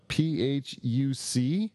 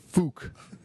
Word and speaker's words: Phuc: it's not fuck, it's fook, right? So then the Vietnamese pronunciation of that is Phuc, not fuck.